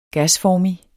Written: gasformig

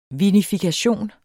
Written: vinifikation

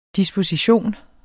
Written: disposition

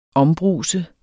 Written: ombruse

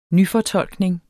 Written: nyfortolkning

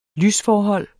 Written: lysforhold

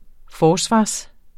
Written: forsvar

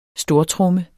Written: stortromme

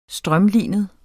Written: strømlinet

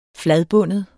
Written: fladbundet

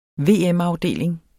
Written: VM-afdeling

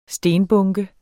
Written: stenbunke

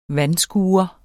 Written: vandskure

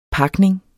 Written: PAKNINGEN

